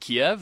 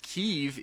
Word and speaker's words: Kyiv: And well, why do I say Kyiv and not Kiev? Because Kyiv is the Ukrainian pronunciation.